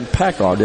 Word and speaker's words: PACCAR: We are at the East Mississippi Community College Communiversity today, and joining us now is James Goodman, the Human Resources Manager at PACCAR.